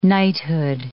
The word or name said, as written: knighthood